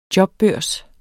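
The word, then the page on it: jobbørs: Danish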